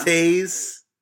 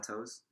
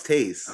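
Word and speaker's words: Toews: Taze. – Are you sure it's not Toes? – No, it's Taze.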